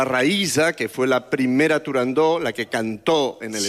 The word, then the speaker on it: Turandot: No se debe pronunciar Turandot. – Turandot. – La ópera se titula Turandot.